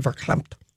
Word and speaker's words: verklempt: But I spelled it verklempt. – Yeah. He'll know what you mean. And I think he'll say it with the correct pronunciation, verklempt.